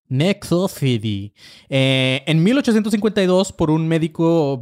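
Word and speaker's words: nécrophilie: El término de necrofilia fue acuñado en francés como necrophilie,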